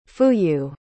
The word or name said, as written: fuyu